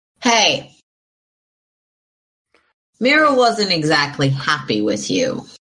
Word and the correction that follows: Mayra: Um, sorry, I mispronounced it. Mayra was the captain of the Thieves Guild, the one who told you that the ASMR you were looking for had gone to Mirror Shore.